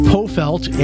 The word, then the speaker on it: Pofeldt: With us for the rest of the hour is Elaine Pofeldt. And Elaine, am I saying your name right? You are. You have got it right. – okay, great. Uh, yeah. So, so the silent D.